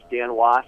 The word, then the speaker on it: Dan Wos: The name's Dan Wash, and it's spelled D-A-N-W-O-S, as in Sam.